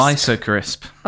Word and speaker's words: Weisse Crisp: How do you say this? Is it Rittersport Weisse Crisp? – Weisse Crisp.